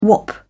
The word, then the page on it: whop: British English